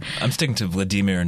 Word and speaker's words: Vladimir: In interviews, he says that the Vladimir should rhyme with redeemer,